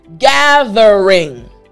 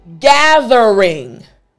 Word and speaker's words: gathering: Now, if you're watching the video version of this lesson, you can see me putting my tongue through my teeth. Gathering. Excellent. Now I want you to repeat after me. Gathering. Excellent. Last time. Gathering.